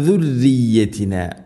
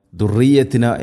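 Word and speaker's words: ذريتنا: അതുപോലെ ദാലിൻ്റെ ഉച്ചാരണവും ആ ശബ്ദം സുറിയത്തിന എന്നോ ദുർറിയത്തിന എന്നോ ആവരുത്. ദുർറിയത്തിന റായിനെ തർക്കീഖ് ചെയ്യണം, നേർപ്പിക്കണം. ദുർരി ഇവിടെ അധികം ആളുകളും ദുർറിയത്തിന എന്ന് പറയാൻ അല്ലെങ്കിൽ ഓതാൻ സാധ്യതയുണ്ട്, ശ്രദ്ധിക്കണം.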